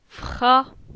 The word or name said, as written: Fra